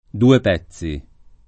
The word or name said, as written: duepezzi